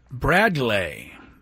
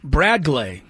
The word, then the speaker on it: Bradgley: The pronunciation, according to Baseball Reference, is Bradley. B-R-A-D, Brad. Glay, G-L-A-Y, Bradley Rodriguez. It's spelled B-R-A-D-G-L-E-Y. Pronounced, though, Bradley Rodriguez.